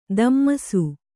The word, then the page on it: ದಮ್ಮಸು: dammasu; Kannada